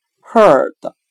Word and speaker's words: herd: 我们用谐音法来记忆这个单词 herd，它的发音很像汉语的“ 鹤” 的吆喝的“ 鹤”。我们这样来联想这个单词的词义：放牧人。通过大声吆喝，使羊群聚集到一起。今天所学的单词 herd，放牧、聚集，我们就可以通过它的发音联想到汉语的“ 鹤” 的，通过大声的吆喝来使羊群聚集到一起。